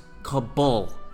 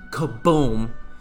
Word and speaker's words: Kabul: It's pronounced Kabul, not Kaboom.